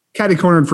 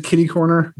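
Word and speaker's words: catty-corner: Catty cornered from here. – I have no idea. – Catty corner. – Catty corner. – Some people will pronounce it kitty corner, but it is.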